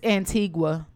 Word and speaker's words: Antigua: And it's Antigua.